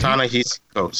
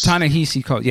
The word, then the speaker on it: Ta-Nehisi Coates: Tashahi Coates. I don't know if I'm saying his name right. I apologize. – But Ta-Nehisi Coates. – Ta-Nehisi Coates.